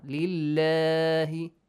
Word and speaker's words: لله: The lam in the word Allah is pronounced lightly with an e sound. لله sound.